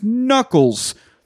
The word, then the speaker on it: knuckles: You know what a silent K is? It's when you don't say the K at the beginning of a word, like brass knuckles.